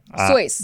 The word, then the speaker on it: Seuss: Yeah, so, his mom's maiden name is Seuss. – Mm. – It's actually pronounced Cease. Cease? – See, we don't even care, do we? – I don't care. – I'm saying how people actually pronounce their shit. – Yeah. – We all just hear what everyone else says and we're like, Soyce. That's it's what pronounced it Soyce.